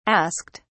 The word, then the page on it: asked: British English